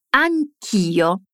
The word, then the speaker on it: anch'io: Fai attenzione alla pronuncia di anch'io, la parola anche da sola ha la lettera E alla fine, ma quando la diciamo insieme a io, togliamo questa E e uniamo tutto, dicendo anch'io.